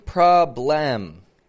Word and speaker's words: problem: Notice, I'm not saying problem. The spelling is B-L-E-M, but a schwa could be any letter. And there it is right there. Problem. Problem. In the second syllable.